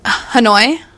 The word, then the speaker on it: Hanoi: So she traveled to Hanoi, which I don't know if that's how you say it. Hanau. It is. No, you killed it. – Hanoi? – Yeah.